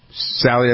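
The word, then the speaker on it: Salley: He's an NBA player, S-A-L-L-E-Y, I don't know him. Riley Cote, Sally, that's how you pronounce his name, yeah? – Yeah, basketballs, yep.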